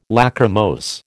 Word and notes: Lachrymose: American English

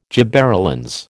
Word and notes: gibberellins: American English

